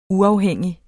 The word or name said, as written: uafhængig